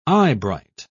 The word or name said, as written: eyebright